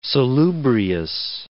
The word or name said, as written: salubrious